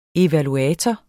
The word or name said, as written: evaluator